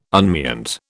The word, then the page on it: unmeant: British English